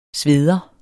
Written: sveder

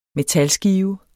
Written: metalskive

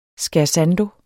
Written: scherzando

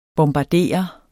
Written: bombardere

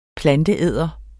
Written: planteæder

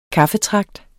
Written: kaffetragt

